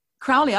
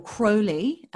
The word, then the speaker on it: Crowley: So Crowley, I first heard, or Crowley, as I've discovered, it's actually pronounced.